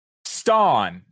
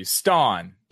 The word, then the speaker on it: Stonn: Stawn. If you need a spelling, I can spell it for you. Stawn. – Please do. – S-T-O. N-N. Stawn.